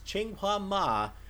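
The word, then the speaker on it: Qinghua Ma: because I had a correction on this pronunciation, I want to make sure I had this right, it's Chinghua Ma making his return to the circuit.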